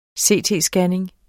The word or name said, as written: CT-scanning